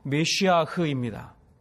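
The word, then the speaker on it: 메시아흐입니다 (מָשִׁיחַ): 기름 부음 받은 자 우리 모두가 잘 아는 것처럼 히브리어로 메시아흐입니다. 제가 발음이 좋아서 잘 못 알아들으시는데 메시아입니다. 메시아. 메시아.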